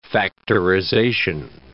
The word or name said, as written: factorization